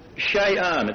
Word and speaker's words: sha'an: It is the Hebrew word she'an. It's spelled S-H-A-A-N.